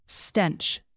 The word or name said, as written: stench